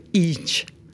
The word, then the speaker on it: hygge: Et j'ai envie de vous parler d'une qui me plaît beaucoup, qui s'appelle le Houg et qui s'écrit H-Y-G-G-E, le Hidge.